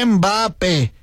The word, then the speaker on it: Mbappé: Y es que ya se lo pegó Miches, justamente aquí nos comenta Miches que le llama el papel, no, Mbappé, es francés, hay que recordar que ahí se pronuncia así.